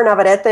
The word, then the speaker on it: Navarrete: It's Jennifer Navarrete, if you can roll your R's. If not, I've also been called Jennifer Never Ready, and that also works.